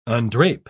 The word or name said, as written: undrape